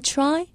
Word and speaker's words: try: Try, T-R-Y, try. 무엇을 시도하다라는 뜻이죠. 발음하실 때 try라고 T 발음을 세게 해서 미국 사람들이 잘못 알아듣는 경우가 있는데요. T와 R을 같이 동시에 발음하셔야 정확한 영어 발음이 나옵니다. Try. Try가 아니라 Try입니다. Try.